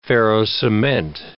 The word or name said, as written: ferro-cement